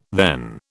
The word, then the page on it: then: British English